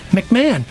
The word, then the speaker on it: McMahon: It's Dave McMahon, M-C-M-A-H-O-N. Proper pronunciation of my last name, McMahon. It's not Dave McMahon. It's just Dave McMahon.